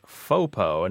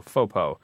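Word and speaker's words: faux-po: The first clue is FOPO, and it's spelled F-A-U-X hyphen P-O, and F-A-U-X being the French for fake, so FOPO.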